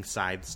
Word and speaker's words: scythe: and he's watching the men cut down the scythes, or cut using scythes to cut down... Scythe, is that how you say it? – Scythe.